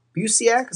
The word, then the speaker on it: Busiek: It's a Superman story written by Kurt Busiek. Is that how you pronounce his name? – As far as I know.